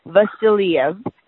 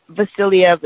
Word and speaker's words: Vasiliev: It's spelled V as in Victor, A, S as in Sam, I, L as in Leo, I, E, V, Vasiliev. And the secretary pronounces it perfectly. Vasiliev is perfect. Polina Vasiliev. – Polina, okay. – Yeah, Polina. P-O-L-I-N-A.